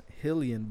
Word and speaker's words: Rilion: And it's his name is Hillian, but in Portuguese, the R's are pronounced with an H. So look up Rillian, R-I-L-I-O-N, Gracie.